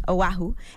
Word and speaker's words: Oahu: Oahu.